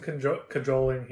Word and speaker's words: cajoling: Do you pronounce the J? – Yes. You absolutely pronounce the J. – You sure? – I'm positive. I am positive. I'm absolutely certain that you pronounce the J in cajoling.